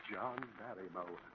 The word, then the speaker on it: Barrymore: John Barrymore. Three syllables. John Barrymore.